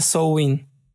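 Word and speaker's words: Samhain: Que se escribe Sam... Ain con una H intermedia, pero se pronuncia Sowin y significa fin del verano, que pues sí.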